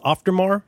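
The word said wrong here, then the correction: Auf der Maur: Auftermauer, yeah.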